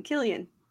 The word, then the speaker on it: Cillian: No, no, that's how he pronounces his name. – That's his actual pronunciation. It's not. – Yes, Cillian? That's the actual pronunciation, Cillian.